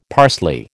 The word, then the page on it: parsley: American English